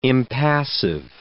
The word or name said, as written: impassive